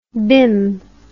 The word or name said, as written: bim